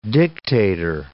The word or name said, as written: dictator